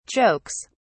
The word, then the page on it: jokes: British English